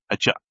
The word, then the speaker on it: Hijab: hijab at all. So we are not really like that strict when it comes to Islam. – What was that word that you were using? Pronounce that word again. Hijab.